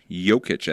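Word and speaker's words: Jokić: Yeah, let's talk about Denver Nuggets Center, uh, Jokic Jokic, as it's pronounced here, is celebrating his NBA title back home in Serbia.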